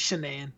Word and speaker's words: Shanann: It's Shanann.